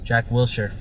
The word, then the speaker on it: Wilshere: Jack Wilshere.